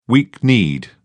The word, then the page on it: weak-kneed: British English